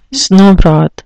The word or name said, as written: snowbroth